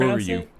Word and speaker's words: Furyu: There's this company called Furyu, I think is how they're pronounced. – Oh, Furyu. Yeah, Furyu.